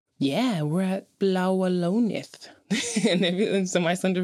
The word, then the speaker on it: Bláa Lónið: The Icelandic word of the episode is how you say the Blue Lagoon in Icelandic. And that is Bláa Lónið. Bláa Lónið.